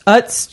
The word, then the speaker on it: Utz: cotton candy peeps, Utz chips.